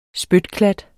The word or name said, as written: spytklat